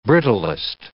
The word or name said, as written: brittlest